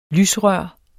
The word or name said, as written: lysrør